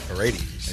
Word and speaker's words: Paredes: Please pronounce Paredes in your native half-sican tongue. – Paredes.